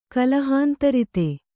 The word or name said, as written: ಕಲಹಾಂತರಿತೆ